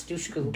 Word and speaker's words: Dushku: No, it's Dushku. – Dushku. Like douche. Okay.